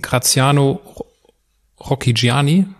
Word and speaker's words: Graziano Rocchigiani: Das haben Sie im Kontext der zwei Kämpfe gegen Graziano Rocchigiani, richtig ausgesprochen, gesagt.